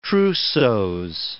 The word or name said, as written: trousseaux